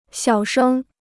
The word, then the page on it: 小声: xiǎo shēng